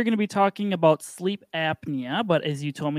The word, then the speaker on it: Sliiip: It's spelled S L triple I P, but pronounced sleep.